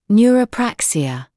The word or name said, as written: neurapraxia